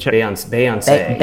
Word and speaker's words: Beyoncé: Beyoncé, I believe. Beyoncé. – Beyoncé. – Beyoncé. – It's a two A's. Yeah. – Okay, okay, all right. So Beyoncé has released an album. Yeah. Good job, Beyoncé.